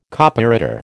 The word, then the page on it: Copyreader: American English